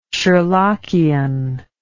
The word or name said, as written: sherlockian